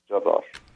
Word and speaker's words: Jarrar: My first name is spelled R-A-E-D, Raed, and the last name is J-A-R-R-A-R, Jarar.